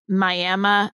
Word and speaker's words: Miami: The book opens up with a trip you take to Miami, Texas.